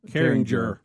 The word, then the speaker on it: Carringer: Yeah, the Carringtons. – Name? Oh, didn't you know, you might be right. – The name is spelled C-A-R-R-I-N-G-E-R. – Carrington. – No, he knows Jer. – Carringer. – Oh, Carringer.